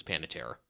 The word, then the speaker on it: Panettiere: That's probably wrong of me, but it's her name, at least she pronounces Panettiere.